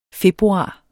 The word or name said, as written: februar